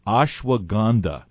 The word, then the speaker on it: Ashwagandha: Ashwagandha and testosterone levels.